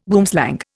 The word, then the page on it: boomslang: Indian